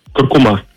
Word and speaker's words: куркума: Куркума. – Давайте разбираться. Секундочку. Несмотря на то, что большинство произносит данное слово с ударением на последний слог, акцент в нем все же стоит на втором.